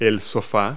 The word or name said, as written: sofa